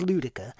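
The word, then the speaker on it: Luedecke: That's L-U-E-D-E-C-K-E. It took me a while to find out how to pronounce it correctly, but it is Ludica, and it's oldmanludica.ca, and that stands for Canada, I assume.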